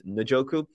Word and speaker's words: Njoku: Okay, David Njoku, I'm going to make sure I'm saying that correctly. I believe that's how I like to say Njoku, but it's Njoku.